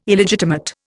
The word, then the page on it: Illegitimate: Indian